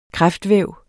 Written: kræftvæv

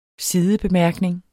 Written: sidebemærkning

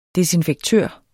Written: desinfektør

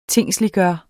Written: tingsliggjort

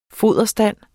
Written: foderstand

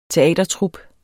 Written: teatertrup

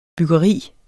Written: byggeri

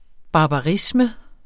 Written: barbarisme

